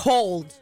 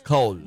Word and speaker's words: cold: sé cómo se pronuncia, pero lo de frío. ¿Cómo se pronuncia? – Cold. – Cold. – Muy distinto.